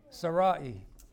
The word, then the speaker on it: Sarai: Sarai is how you say it.